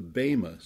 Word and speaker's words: Bema: That is the Bema seat, B-E-M-A, long E, Bema seat.